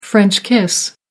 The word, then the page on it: french kiss: American English